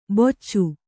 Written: ಬೊಚ್ಚು

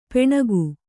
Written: ಪೆಣಗು

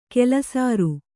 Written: ಕಲ್ಲುಸಾರ

